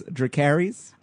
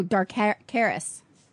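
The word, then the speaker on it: Dracarys: It says Drakaris. – Oh, Drakaris. – Drakaris. – Yeah. – Oh, yes. Excuse me, Miss Drakaris, for mispronouncing it.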